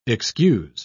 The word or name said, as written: excuse